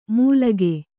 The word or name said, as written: ಮೂಲಗೆ